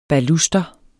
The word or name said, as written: baluster